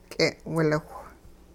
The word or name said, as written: k'e'wiliwh